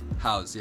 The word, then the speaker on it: Haus: We're just going to play, how do you pronounce it? Haus? – Haus, yeah.